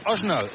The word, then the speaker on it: Arsenal: Es Osnal. El Arsenal es Osnal.